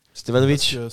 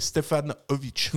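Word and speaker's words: Stevanović: Ah bah, On espère qu'un jour les consultants arriveront à, à, prononcer son nom convenablement. – Stevanovic Selon Barella, c'est Stefanovic.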